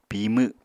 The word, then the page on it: pimw: Apatani